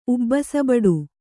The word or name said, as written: ಉಬ್ಬಸಬಡು